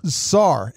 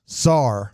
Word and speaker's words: Sarr: Is it Czar? Czar? – It's the Czar of Russia. – Czar. S-A-R-R, right? – Yeah, Czar. – No Z, just an S. – Czar. – Yeah, Czar.